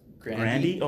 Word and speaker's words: Grande: You know her last name is pronounced Grandy? – Oh, Grandy.